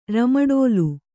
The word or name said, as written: ರಮಡೋಲು